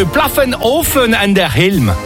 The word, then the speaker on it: Pfaffenhofen an der Ilm: Et je vous emmène du côté de Pfaffenhofen an der Ilm.